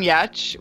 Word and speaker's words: Giertz: My name is Simone Yetch, which is a very unintuitive pronunciation of my last name, which is spelled G-I-E-R-T-Z.